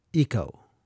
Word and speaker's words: Ico: it is a game called, I think you pronounce it Ico or Ico. It's spelled ICO.